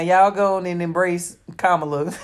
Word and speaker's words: Kamala: Kamala will glow up. – Look how you say her name. Everybody got to think about how to say her name. We want to call that lady Kamala.